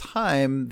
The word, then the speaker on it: Haim: Well, it's Haim, the Israeli Jewish. I'm not sure which language it is because there's a couple of languages, but the Jewish word for life is Haim, and that's what it's from.